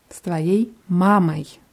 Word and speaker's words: с твоей мамой: С твоей мамой. – Мамой. – Ja, die Betonung ist auf A, also wird oi auch eher wie A ausgesprochen.